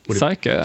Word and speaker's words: PsychÖ: How do I pronounce it properly in Swedish? – PsychÖ. – PsychÖ. PsychÖ. Okay.